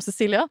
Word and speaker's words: Sicilia: Eh, på norsk som Sicilia. – Altså, her må du enten velge den italienske ja. eller den norske. Du kan ikke bruke en engelsk Nei, uttalen av et italiensk by.